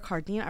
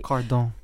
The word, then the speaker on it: Cardin: But real quick, I just want to talk about Pierre Cardin. I really, really hope I'm saying that right. – Cardin. – You're not.